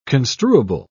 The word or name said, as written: construable